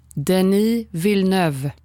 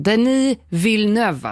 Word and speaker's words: Denis Villeneuve: Jag var osäker på hur det uttalades. – Denis Villeneuve. – Yes, hörde det första gången. Denis Villeneuve alltså, och inget annat.